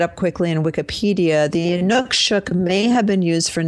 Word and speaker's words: Inukshuk: And this is where he was talking about and pointing to, and I told you I'd find out how to say it, Inukshuk. So it's Inukshuk.